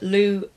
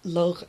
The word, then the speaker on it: Lugh: Suffice it to say that Llew, or Lugh, as it would be pronounced in Old Irish, but most people are familiar with him as Llew, was the hero of the Tuatha De Danann.